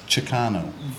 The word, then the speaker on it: Chicano: Get all them Jacanos. – The Jacamos. – Jacamo. Jacamo. Jacamo. – Chicano. – Fucking spell it, please. One time. One of you three spell the word. – C-H-I-C-A-N-O. – One more time. – C-H-I. Chicano. Yes. C-H-I. C-A-N-O, Chicano.